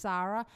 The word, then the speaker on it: Sara: But it's pronounced Sarah. – Mm-hmm. – But I appreciate the effort.